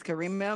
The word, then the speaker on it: Kareemah: Yes, Kareemah Emordi.